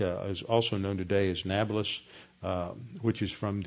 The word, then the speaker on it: Nablus: Neapolis, Arabs in Arabic, they can't pronounce a P, so it's changed to a B, so you get Neapolis or Nablus, meaning new city.